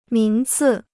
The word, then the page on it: 名次: míng cì